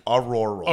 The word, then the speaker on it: auroral: I know I said it wrong, but honestly, now that I said that, it sounds really hot. I need to look into this more. – It's high frequency active auroral.